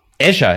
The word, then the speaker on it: Azure: Azure ist es wirklich, schreibt man wirklich Azure.